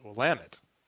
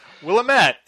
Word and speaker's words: Willamette: You will know it as Willamette.